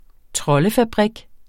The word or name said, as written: troldefabrik